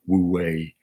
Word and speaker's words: Wu Wei: Wu Wei, is the playfulness of improv.